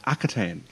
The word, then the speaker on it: Akhaten: It's my great pleasure to be here today in the camper van alongside my good friend James to review The Rings of Akhaten.